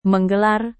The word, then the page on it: menggelar: Indonesian